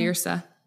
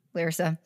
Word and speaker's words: Lyrsa: Like, Larissa? Wait, Lysa? How do you say her? Lyrsa. – Lyrsa.